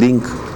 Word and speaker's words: link: Al pronunciarse en español, el acento o la mayor fuerza de voz recae en la última sílaba, o sea, en link. Es una palabra aguda, tiene acento prosódico, significa que se carga la voz pero no lleva tilde.